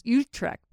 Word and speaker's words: Utrecht: Not Utrecht. That's what we thought it was, but we were corrected that the right pronunciation is Utrecht.